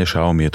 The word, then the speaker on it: Xiaomi: A správne vyslovanie Xiaomi je to veľmi jednoduché.